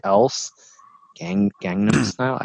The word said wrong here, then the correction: Gangnam: I said that wrong. – Gangnam. I've been saying it wrong too. Gangnam. – I had a D. I threw an extra D sound. – I've been saying Gangnam. – There's not a singular D sound.